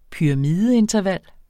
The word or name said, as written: pyramideinterval